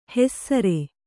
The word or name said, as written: ಹೆಸ್ಸರೆ